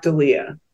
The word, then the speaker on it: D'Elia: This is horrible, but tell me how Sophia pronounces her last name. – Sophia Black D'Elia. – D'Elia.